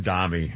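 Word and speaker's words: Mamdani: You think that Mamdami has, how do you pronounce his name? Mamdami? – Mamdami, yeah.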